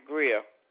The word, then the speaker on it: Grier: May I have, let's spell that last name, G-R-I-E-R? – Uh-huh, Greer.